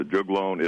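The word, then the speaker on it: juglone: Yeah, you pronounce it jugalone? Is that how you pronounce it? – Yeah, jugalone.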